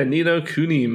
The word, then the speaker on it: Ni no Kuni: Yeah, Ni No Kuni, man.